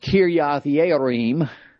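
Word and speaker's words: Kiriath-Jearim: And verse 21, they sent messengers to the inhabitants of Kirjath-Jearim, or it's pronounced Kiriath-Yearim, saying, the Philistines have brought again.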